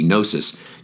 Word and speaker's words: gnosis: And the Greek for knowledge is gnosis, G-N, the silent G, gnosis, G-N-O-S-I-S would be how we would spell it.